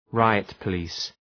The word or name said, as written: riot-police